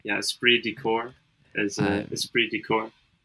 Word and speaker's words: esprit de corps: The third word is C-O-R-P-S. Lots of extra letters here that do not get pronounced.